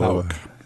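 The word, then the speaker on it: Fouke: Oh, and in Holland, they pronounce it Foka. And for some reason, when my ancestors came over and settled here in the States, they changed it to Fauk. – So, Fauk.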